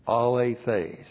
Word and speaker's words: alethes: Keeps on being alethes. A-L, long E-T-H, long E-S. Alethes.